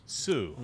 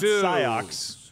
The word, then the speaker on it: Sioux: Sue. – Sue. – Sue. Sue. – Not Psyox. Sue.